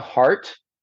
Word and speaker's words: Heart: It's called Mr. America Heart. My last name is spelled H-E-A-R-T.